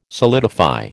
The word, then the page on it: solidify: American English